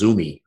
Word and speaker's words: Zume: I think the best way to pronounce it so that people can find it is Zumi, right? Z-U-M-E, Zumi Pizza out of California.